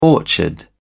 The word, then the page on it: orchard: British English